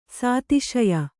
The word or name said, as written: ಸದಸ್ಯೆ